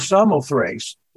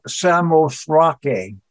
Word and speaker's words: Samothrace: It's called that because in verse 11, it says, from Troas, we put out to sea and sailed straight for Samothrace, or Samothrake is the correct pronunciation.